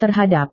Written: terhadap

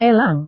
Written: elang

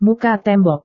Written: muka tembok

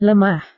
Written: lemah

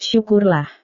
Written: syukurlah

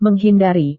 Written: menghindari

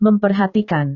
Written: memperhatikan

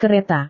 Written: kereta

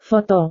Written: foto

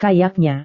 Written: kayaknya

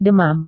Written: demam

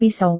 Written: pisau

